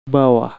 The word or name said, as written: bawah